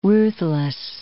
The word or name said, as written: ruthless